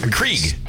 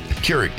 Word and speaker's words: Keurig: Johnny brought in a coffee maker because Krieg. – Krieg. – Keurig.